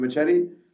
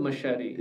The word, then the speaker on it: Machete: Machete. Oh, is it is it machete? machete. Yeah. Oh, that's how you say it. I don't know. Okay. How do you say it? Otherwise? I only machete? heard yeah. it as machete. – Machete. you See, machete. Yeah.